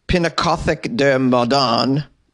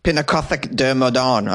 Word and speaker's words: Pinakothek der Moderne: Visitors to Munich's Pinakothek der Moderne. Did I say that correctly? Why did I say that with a French accent? Pinakothek der Moderne.